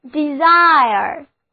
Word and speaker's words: desire: 字母组合 IRE 发音是 IRE, 重音放在第二个音节上，单词就读作 desire，意思是愿望、心愿。